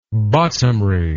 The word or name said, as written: bottomry